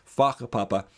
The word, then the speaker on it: whakapapa: Or in Aotearoa New Zealand, there's in Maori culture, there's a concept of whakapapa, which is spelled with a WH but pronounced with an F, whakapapa.